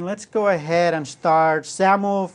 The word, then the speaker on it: XAML: And it's pronounced XAML. Kind of sounds like you're pronouncing with a Z. So XAML. And all it is a serialization and initialization format.